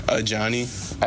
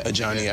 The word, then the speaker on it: Ajani: Like Johnny, basically, but just with an A in front of it. – Oh, okay. Ajani. Yeah. I thought it was, like, Ajani. Like, some people that have that name pronounce it that way, but, yeah, I just go by Ajani.